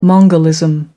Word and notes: mongolism: American English

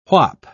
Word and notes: whap: British English

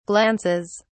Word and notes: glances: British English

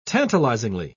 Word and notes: tantalizingly: British English

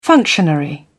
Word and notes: functionary: British English

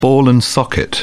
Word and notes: ball-and-socket joint: British English

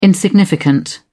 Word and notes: insignificant: British English

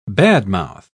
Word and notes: badmouth: British English

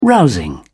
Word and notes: rousing: British English

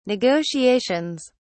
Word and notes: negotiations: British English

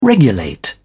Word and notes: regulate: British English

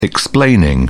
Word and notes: explaining: British English

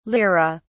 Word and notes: lire: British English

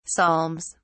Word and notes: psalms: British English

